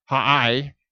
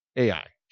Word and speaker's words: Ai: It's spelled Ai. It's actually pronounced in Hebrew, Ha'ai, but it's better in English, Ai. Just letter A, letter I, Ai. Just do it that way. That's the traditional way of saying it in English.